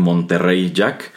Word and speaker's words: Monterey Jack: que son Monterey Jack, aunque aquí sí se pronunciaba como tal Monterrey Jack.